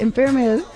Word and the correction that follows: Intramuros: Oh, Intramuros. – Intramuros.